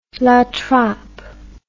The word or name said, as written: trappela